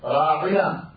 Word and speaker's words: راعنا: اور صرف راعنا بغیر یا کے اگر پڑھا جائے، راعنا جو قرآن کریم میں ہے جس کی صحیح تلفظ یہ ہے، اس کا مطلب یہ ہے کہ آپ ہماری رعایت کیجیے، آپ ہمارا لحاظ کیجیے، آپ ہماری طرف نظر فرمائیے.